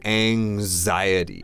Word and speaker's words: anxiety: So the word here, the key word is anxiety. – 这个词的发音我们要跟大家讲一下。首先它的拼法是 anxiety. – Right. – 那这里这个 X 的发音是发 Anxiety. Like xylophone.